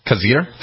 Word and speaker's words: Khizr: K-H-I-Z-R. Khizr Khan? – Khizr? – Khizr Khan.